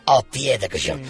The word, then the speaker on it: Au Pied de Cochon: Que en Iguas, que no festejaron en Au Pied de Cochon. Lo dije bien, ¿verdad? Au Pied de Cochon.